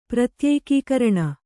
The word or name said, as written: ಪ್ರತ್ಯೇಕೀಕರಣ